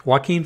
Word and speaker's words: Joaquin: It is featuring Quinoa Phoenix as am I pronouncing that right, Noah? – No, you're not. – He is playing a character named Arthur Fleck. Joaquin Phoenix is playing a character named Arthur Fleck, who is a wholly new creation.